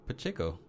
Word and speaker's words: Pacheco: Pacheco. Yeah, Carlos Pacheco. That's how I say it.